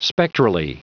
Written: spectrally

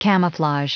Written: camouflage

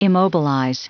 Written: immobilize

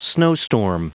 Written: snowstorm

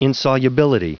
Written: insolubility

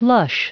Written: lush

